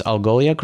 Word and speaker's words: Algolia: First of all, did I pronounce Algolia correctly? – Bang on. Yeah, that's an easy one to typo and also to get wrong when you're speaking publicly.